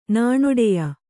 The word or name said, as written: ನಾಣೊಡೆಯ